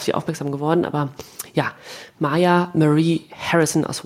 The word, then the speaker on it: Mya: Es ist ähm, Maya, wird sie, glaube ich, ausgesprochen. Mia geschrieben, M-Y-A, Maya.